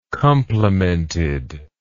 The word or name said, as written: complemented